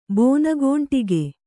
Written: ಬೋನಗೋಂಟಿಗೆ

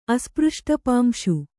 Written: ಅಸ್ಪøಷ್ಟಪಾಂಶು

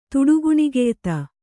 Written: ತುಡುಗುಣಿಗೆಯ್ತ

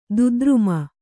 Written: ದುದ್ರುಮ

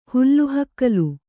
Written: ಹುಲ್ಲುಹಕ್ಕಲು

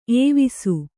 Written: ಏವಿಸು

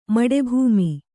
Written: ಮಡೆಭೂಮಿ

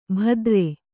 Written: ಭದ್ರೆ

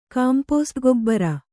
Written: ಕಾಂಪೋಸ್ಟ್‍ಗೊಬ್ಬರ

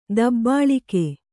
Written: ತಬಲಕು

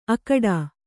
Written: ಅಖೋಟ

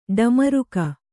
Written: ಡಮರುಕ

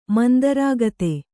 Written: ಮಂದರಾಗತೆ

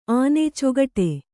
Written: ಆನೆ ಚೊಗಟೆ

